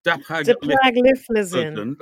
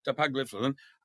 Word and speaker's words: dapagliflozin: I got better, but then I started taking this latest one, I can never pronounce it, Dapagliflozin. – That's right, Dapagliflozin.